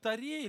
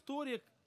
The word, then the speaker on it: Торе: Друзья, мы говорим о том, что в Торе или в Торе, и то, и другое произношение правильное, мы говорим, что в этой пятикнижии сокрыто Евангелие.